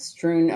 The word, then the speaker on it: strewn: On the tables and crayons strewed across the floor. Strewn. I didn't pronounce that neat. That N very well. Do I need to say that again? – Yeah. – And crayons strewn across the floor.